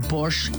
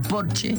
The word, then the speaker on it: Porsche: O decir que tengo un Porsche en lugar de decir tengo un Porsche, porque en alemán Porsche se dice Porsche porque todas las es al final se pronuncian, como Marlene Dietrich, por ejemplo, que todo el mundo dice Marlene, pero es Marlene.